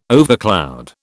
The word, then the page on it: overcloud: British English